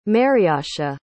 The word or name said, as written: mariasha